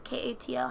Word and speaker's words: Kato: And basically, the whole thing talks about how their Akita dog named uh, Kato, it's K A T O, how would you say that? – Kato.